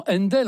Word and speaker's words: Handel: Händel, H-A-N-D-E-L, ça se prononce tout naturellement Händel en anglais. – Ah bah oui. – Donc Händel. Ils n'ont besoin de rien, eux. Donc maintenant on l'écrit H-A-N-D-E-L, et alors, son prénom, qui était...